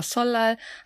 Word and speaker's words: Seollal: Und Null ist einfach das Wort für Tag, dass wenn L und N aufeinandertreffen, dass das N immer zu einem L wird. Deswegen Chilimjok oder Solal hat man halt dieses Doppel-L, weil das eine einfachere Aussprache ist.